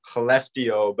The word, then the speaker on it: Skellefteå: but Skellefteå, yeah. I believe is how you say it. Was it close? – It's close. I usually say it's Skellefteå, but it's spelled like Skellefteå.